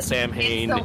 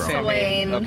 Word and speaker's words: Samhain: I think it's pronounced Samhain, right? Or is it pronounced Suwain? – I pronounce it Samhain. It could be wrong. – It's Suwain.